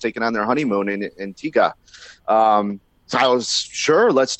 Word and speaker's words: Antigua: But as soon as I arrived, I got corrected in the correct pronunciation is Antigua. I guess they don't like to pronounce the U.